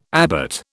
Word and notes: abbot: British English

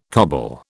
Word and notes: coble: British English

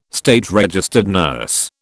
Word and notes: SRN: British English

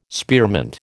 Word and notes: spearmint: American English

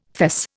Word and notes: fess: Indian

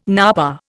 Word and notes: napa: Indian

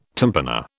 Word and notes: tympana: British English

